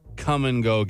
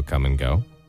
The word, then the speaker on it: Kum & Go: Well, Iowa man arrested for having self-fun at a come-and-go gas station. – If you've ever been to the United States and been to a come-and-go, it's spelled differently. – K-U-M.